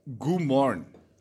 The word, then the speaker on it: Guan: Estomich is saying it's pronounced Gumorn. – Gumorn. – G-U-A-N. Now, take all those silent letters out of there. What are they doing in there? Just confusing me.